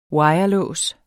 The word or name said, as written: wirelås